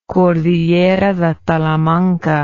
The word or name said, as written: cordillera de talamanca